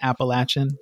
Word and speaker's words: Appalachian: Um, there is some discussion about pronunciation. I'm told it's Appalachian, but you know we we can talk about that too.